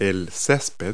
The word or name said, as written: lawn